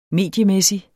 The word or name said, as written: mediemæssig